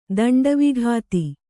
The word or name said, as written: ದಂಡವಿಘಾತಿ